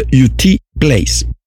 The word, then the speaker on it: U.T. Place: En inglés eh, se pronuncia U.T. Place.